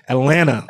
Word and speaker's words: Atlanta: So if people know, you don't say the T. If you're from Atlanta, you don't say the T. Atlanta.